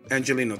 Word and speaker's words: Angeleno: How do you say it? – Angelino.